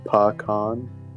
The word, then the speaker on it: pecan: Pronounce pecan or pecan? Pecan. It's pa-con. Pecan.